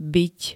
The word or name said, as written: byť